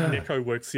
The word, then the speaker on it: Neko.works: And not like Neko Works the other day when specifically on their website they said Neko.works, you pronounce the dot.